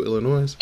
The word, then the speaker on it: Illinois: This is one of our big TakeOver weekends coming to us from the home of Jade to the Mac, Chicago, Illinois. – So, not Illinois. – Oh, the S is silent.